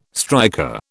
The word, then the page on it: striker: British English